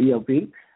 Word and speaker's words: El-P: I said it right this time, EL. I said EIP in the interview. It was funny. But ELP, great rap artists, you know, grassroots, independent.